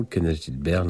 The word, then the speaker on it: Kenneth Gilbert: Kenneth Gilbert, l'un des grands noms du clavecin, né à Montréal. Il tenait d'ailleurs à ce que l'on prononce son nom à la française, car il était québécois.